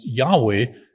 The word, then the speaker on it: Yahweh: In fact, the name of God, usually written as YHWH, usually pronounced Yahweh, but the word was regarded as so sacred that it couldn't even be uttered by sinful human lips.